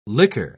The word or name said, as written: liquor